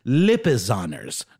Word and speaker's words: Lipizzaners: and it is Lipizzaners, not Lipasners.